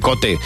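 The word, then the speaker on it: Kvothe: ¿Quoth? – Quoth se dice. Cuando te lees el libro te das cuenta de cómo se pronuncia, porque se pone Cote, pero bueno, hay quien le llama Cote también.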